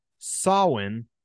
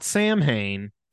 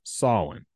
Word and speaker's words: Samhain: So Danzig is an American heavy metal band led by former, I'm going to say it again, Samhain is how it's pronounced, not Samhain. It's pronounced Samhain.